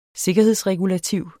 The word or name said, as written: sikkerhedsregulativ